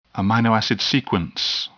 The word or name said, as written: amino acid sequence